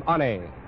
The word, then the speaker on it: Ane: A-N-E pronounces his name Anne from Honolulu.